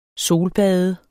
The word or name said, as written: solbad